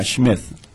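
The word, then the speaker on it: Schmitt: Conocí a un astronauta, nunca puedo pronunciar el nombre porque es Smith, pero la versión alemana, Schmidt. – Harrison Schmidt.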